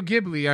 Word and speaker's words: Ghibli: uh, So um, Studio Ghibli.